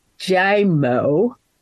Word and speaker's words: Giaimo: In 1980, um, Robert, let me get this spelled right, Giaimo, it's spelled G I A I M O, a Democrat from Connecticut, and Henry Bellmon, a Republican from Oklahoma left Congress.